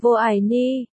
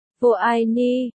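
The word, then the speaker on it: Vô Ai Ni (我爱你): Tiếng Trung đọc là Vô Ai Ni, Vô Ai Ni, 1314, Y San Y Si, giống với, Y Sân Y Si, ngụ ý, suốt đời.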